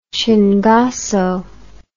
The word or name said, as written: chingazo